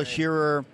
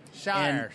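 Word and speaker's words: Shirer: But Priscilla Shearer. – Shire.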